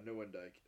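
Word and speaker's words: Nieuwendyk: It was an honorable Joe Newendike. Yeah, Newendike. That's how you say it.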